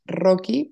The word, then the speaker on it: Rocchi: Eh, en Instagram, bueno, pues me pueden encontrar como arroba Dani con una N Y guión bajo, mi apellido que es Rocky, pero se escribe Rochi con doble C.